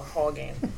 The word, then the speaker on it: Haugen: It's spelled H-A-U-G-E-N. So I'm going to call her Frances Hogan or Hogan. Okay. – Like, and Hulk Hogan.